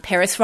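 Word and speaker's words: Perris: And my Twitter handle is just Helen Peris, H-E-L-E-N-P-E-R-R-I-S. Peris rhymes with Ferris, as in Ferris wheel or Ferris Bueller.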